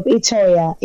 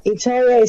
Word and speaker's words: Itoya: And you can find me on Instagram with the same name, Love Itoya. Itoya is spelled I-T-O-Y-A, because people always like to get it wrong.